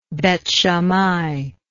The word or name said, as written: Beth Shammai